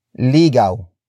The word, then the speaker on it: ligaw: And that is ligaw ligaw, ligaw.